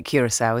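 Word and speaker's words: Curaçao: It is how you say it, Curacao, isn't it? – I think it is, yes.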